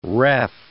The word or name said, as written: ref